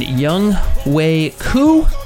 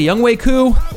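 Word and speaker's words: Younghoe Koo: And Young Wei Koo, only took me 12, 13 weeks to say it correctly. Young Wei Koo kicking for Gordon Lucko, his MVP on the season.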